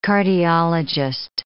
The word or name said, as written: cardiologist